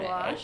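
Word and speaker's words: Baloche: Yeah, I like that better than the, what is it, Paul Blush? Blush? How do you say his name? I think he wrote Blush. – Blush.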